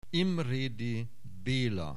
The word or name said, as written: Imredy Bela